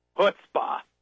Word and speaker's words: chutzpah: That's what we call chutzpah. Or right. – If you say it correctly, let me get my throat just right. Chutzpah.